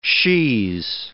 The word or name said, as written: she's